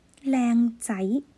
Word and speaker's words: 靓仔: 大家听好了，靓仔，靓仔，在字面上应该大家都很熟悉。